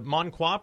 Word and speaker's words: Monqua: And I'm going to mangle the pronunciation, and I apologize. It's the Monqua Project? Is that even close? – Yeah, and it actually means the gift.